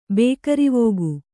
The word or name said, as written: ಬೇಕರಿವೋಗು